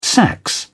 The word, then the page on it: sax: British English